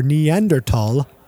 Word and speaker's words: Neanderthal: Researchers are even using CRISPR to learn more about our Neanderthal. I pronounced that the correct way. – Yeah, you did, buddy.